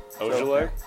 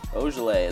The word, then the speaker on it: Ojeleye: Ojeleye. – Ojeleye. There it is.